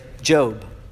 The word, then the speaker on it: Job: It reminds me of Job. In the book of Job, it's spelled J-O-B like job, right?